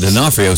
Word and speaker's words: D'Onofrio: It's got a D, comma, O, and then N-O. It's D-O and the O. Gosh, you look at it, you can't pronounce it. But D'Onofrio sounds simple enough.